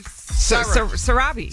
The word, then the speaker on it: Sarabi: How do you pronounce it? Sarabi? – Sarabi. – Sarabi, yeah, there you go.